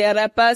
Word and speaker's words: arepa: I just tried the arepa, so I understand the arepa power now.